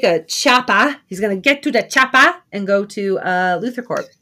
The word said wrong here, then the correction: chopper: Well, you said you gotta leave the ah out of there. It's chopper.